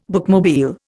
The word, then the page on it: bookmobile: Indian